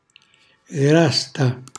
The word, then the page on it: grasta: Calabrese